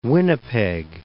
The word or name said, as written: Winnipeg